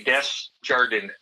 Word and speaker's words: Desjardins: Pretend it's not French, like the bank, and just say Desjardins.